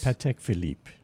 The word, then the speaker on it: Patek Philippe: So they were selling all these big sought-after watches, Rolex Daytonas, Patek, I think that's how you pronounce it, Nautilus. – Patek Philippe.